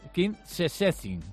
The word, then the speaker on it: Szczecin: El equipo malagueño recibe, a ver si lo digo bien, al King Szczecin de... Sí, sí, así. Me he enterado que en polaco... – A ver, repítelo. – King Szczecin.